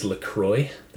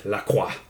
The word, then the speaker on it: La Croix: First, we have a can of unflavored La Croix, or some people know it as La Croix. That'd be the American pronunciation. I'm half French-Canadian, so I have to say it. La Croix.